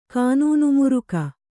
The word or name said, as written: ಕಾನೂನುಮುರುಕ